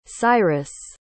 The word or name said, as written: cyrus